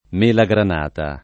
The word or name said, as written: mela granata